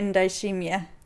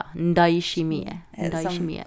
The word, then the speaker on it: Ndayishimiye: Evariste Ndayishimiye. – Ja, Ndayishimiye.